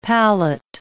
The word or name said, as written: pallet